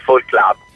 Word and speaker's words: Folk Club: Io direi in piacentino, visto che io sono di Piacenza, visto che canto e scrivo in dialetto piacentino, direi, sì, che piacentino è forse il linguaggio, l'accento più giusto da utilizzare. Comunque è folk club.